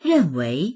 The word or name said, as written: ren4wei2